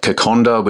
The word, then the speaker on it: kokoda: you know, the they've got like Kokonda, which is it's spelt Kokoda, but Kokonda's their sort of national dish.